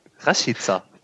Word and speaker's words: Rashica: Raschika. – der gute alte Raschika. Falsch gesagt. Ist ja anders. Raschica.